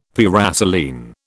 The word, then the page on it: paraselene: British English